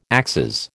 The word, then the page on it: axes: American English